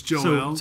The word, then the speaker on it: Joel: I know that we pronounce Joel, but you know, in Hebrew, anytime you see like an E-L at the end of a name, that it's L, right? – Yeah. So it's Joel. – So Joel means Yahweh is God.